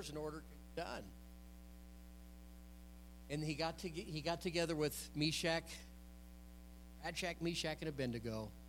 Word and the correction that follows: Shadrach: Am I saying it wrong? Radshack, Meshach, and Abednego, is that? Shadrach, Meshach. Yeah.